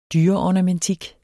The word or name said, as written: dyreornamentik